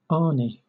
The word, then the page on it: ani: British English